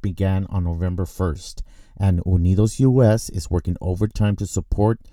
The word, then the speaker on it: UnidosUS: Yeah, UnidosUS.